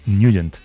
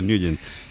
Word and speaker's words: Nugent: Hombre, siempre lo hemos llamado en el barrio Ted Nugent o Ted Nugent. – Claro, ¿no? Yo era de Ted Nugent. – Pero la pronunciación correcta es Nugent. – Te fuiste a Washington y descubriste que era Nugent.